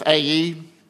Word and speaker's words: Ai: And in the city of Ai, which apparently is how you pronounce it, because God had withdrawn his support over this act of disobedience.